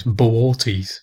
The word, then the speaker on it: Boötes: is is Bootes, which is... Bootes, so it looks like booties or boots, but apparently you're supposed to pronounce the two O's separately, so it's Boötes.